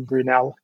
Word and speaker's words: Brunel: It's pronounced Brunel, isn't it? Brunel University. – Yeah.